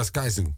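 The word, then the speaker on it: Skyzoo: En dan, ja, de volgende nummer die we gaan afspelen is van Apollo Brown, Sky Zoo. Zeg ik het goed? – Ja, Sky Zoo.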